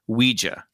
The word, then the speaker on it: Ouija: Ouija, but I think it's supposed to be we and ya or ja. So it's it is pronounced, if you're pronouncing correctly, Ouija.